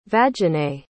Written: vaginae

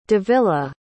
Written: davila

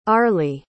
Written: arly

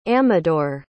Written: amador

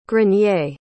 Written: grenier

